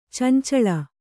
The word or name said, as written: ಚೆಂಚಲಿ